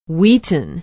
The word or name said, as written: wheaton